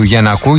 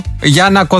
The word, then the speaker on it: Γιαννάκο: Μανιάνα μου, ποτέ δεν θα μάθω που τονίζετε το επώνυμο του κυρίου Γιαννάκου. Γιαννάκος μου λέει Μανιάνα λέγεται. Τον λέμε Γιαννάκο, Γιαννάκο. Γιάννακο δεν τον έχουμε πει ποτέ, αλλά Γιαννάκο, Γιαννάκο τον λέμε.